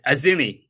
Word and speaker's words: Azumi: By the way, it's Azumi. You, you pronounce it Azumi. Oh, I, I, have no idea. I it's it just reads AZM.